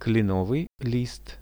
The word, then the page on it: klenovyj list: Russian